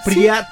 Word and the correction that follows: Pripyat: Pripyat. – Sí, trae el acento en la primera.